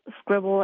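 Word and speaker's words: Skribbl: Okay, and finally, am I pronouncing this right, Scribble? – Yes, it is called Scribble, and it is exactly what it sounds like.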